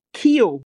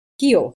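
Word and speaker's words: Keough: Wird sie Kyo ausgesprochen oder wird das ausgesprochen? – Kyo. – Kyo, genau, erzähl mal.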